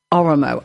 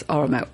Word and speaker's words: Oramo: Oromo. I'm presuming that's where the emphasis is. Oromo. O-R-A-M-O.